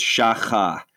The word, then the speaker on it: shacha: but the first one, I think it's pronounced shaka. Is that right? – I'm gonna throw in a little phlegm here, you know, as a good Jewish man. Here it is, shacha, way deep down in the throat, shacha.